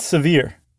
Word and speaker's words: Sevier: How do you pronounce that? Is it Sevier County? – I think it's Sevier. – Oh, it's just Sevier. – I'm almost positive it's Sevier. – Okay, S-E-V-I-E-R.